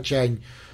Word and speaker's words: чань: Да нет, тут даже, тут, ну, по сути дела, да, я так понимаю, что идея вот того, что называется дзен, а в Китае, собственно, название этот иероглиф читается как чань.